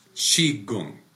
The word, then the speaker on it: Qigong: Well, I don't say it the way a Chinese person would say it. It's Qigong really is Qigong. Is how it's pronounced. Qigong. – So okay. Americanized is Qigong? – Qigong, yeah.